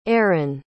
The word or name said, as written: Aron